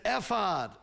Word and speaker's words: ephod: that he came down with an ephod. I've pronounced that ephod before. Ephod is right.